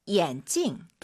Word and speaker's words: 眼镜: 眼镜. – 대체 뭐가 달라요? – 发音不一样. – 네, 발음이 사실 다르대요. – 声调. – 성조. 네. 안경은 찡이 사성이에요. 眼镜.